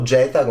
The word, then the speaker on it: Geta: e sono Geta, che noi pronunciamo Geta come imperatore,